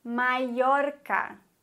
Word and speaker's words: Mallorca: Regel Nummer 1, Doppel-L, genauso wie auch Y, wird wie ein deutsches J ausgesprochen. Doppel-L. Beispiele dafür wären llamarse, heißen, oder Mallorca, Mallorca, oder llegar, ankommen.